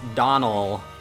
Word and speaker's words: Domhnall: Anyway, they said that it's pronounced Donal, D-O-N-A-L, you go. Donal Gleeson. – Okay, just a silent M-H, that's normal.